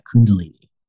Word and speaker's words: Kundalini: I think I would do like Kundalini. I think I said that right. – Yes.